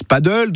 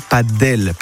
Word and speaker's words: padel: Tout le monde, enfin certains disent paddle, d'autres padel.